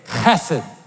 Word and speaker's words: chesed: You could literally pronounce this Hesed. Everybody turn to your neighbor and say, chesed.